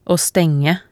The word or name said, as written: å stenge